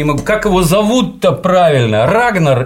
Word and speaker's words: Рагнар: Рагнар на «а», на первый слог впереди, на «ра» ударение.